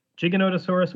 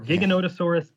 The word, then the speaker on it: Giganotosaurus: So I said, take your pick, Giganotosaurus or Giganotosaurus. Either one is fine.